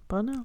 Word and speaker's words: Bonnot: She began her cooking career in New Orleans as the apprentice to Chef Daniel Bonneau. – How do you spell it? – B-O-N-N-O-T. – N-N-O-T? – Mm-hmm. – Yeah. Bonneau. – Oh, my God. I said something French, right? – B-O-N-N-O-T? – Yes.